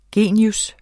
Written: genius

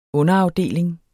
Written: UNDERAFDELINGEN

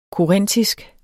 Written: korintisk

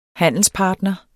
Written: handelspartner

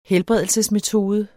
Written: helbredelsesmetode

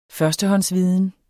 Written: førstehåndsviden

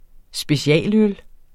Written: specialøl

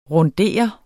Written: RUNDERET

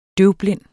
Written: døvblind

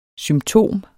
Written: symptom

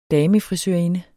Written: damefrisørinde

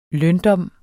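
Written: løndom

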